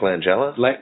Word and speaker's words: Langella: I'll write it down for you before you call his agent again. Langella.